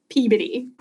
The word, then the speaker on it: Peabody: We're very precious in Massachusetts about how to pronounce all the town names. It's definitely Peabody.